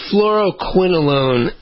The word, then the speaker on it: fluoroquinolone: If you're just joining us, I were talking about antibiotics that could cause aortic aneurysms, the fluoroquinolone. Is that, am I pronouncing it correctly? – Good job. Right. This fluoroquinolone, it's a group of antibiotics that are commonly used to treat a variety of illnesses such as respiratory and urinary tract infections.